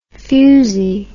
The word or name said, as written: fusi